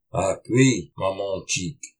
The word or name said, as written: Áhqi mámôciq!